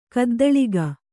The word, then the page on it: ಗದ್ದಲಗೈ: Kannada